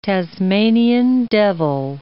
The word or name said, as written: Tasmanian devil